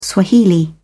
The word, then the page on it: swahili: British English